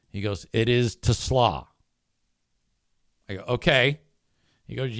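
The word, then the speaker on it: Isaac TeSlaa: Isaac Tesla is officially how you pronounce that guy's name. It's spelled T-E-S-L-A-A, capital T, lowercase E, capital S-L-A-A.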